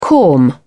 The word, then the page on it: corm: British English